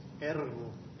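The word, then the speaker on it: Ergo (ἔργον): Egron, is that how you say it? – What's that? – Egron? – Ergo. – Ergo? Yeah, ergo. It comes from ergo.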